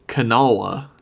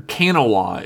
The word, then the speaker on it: Kanawha: Kanawha is what it's actually, or Kanawha is how it's actually but supposed to be pronounced.